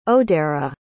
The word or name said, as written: odera